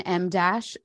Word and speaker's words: em dash: It's actually called an M dash and a Molly dash. – It's spelled EM dash weirdly.